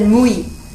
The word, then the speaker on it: mui: Now in Bengali, that's not said Muni, it's said Mui.